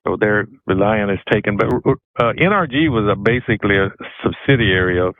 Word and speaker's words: NRG: Where people mispronounce it and say energy. – Energy. – But it was NRG.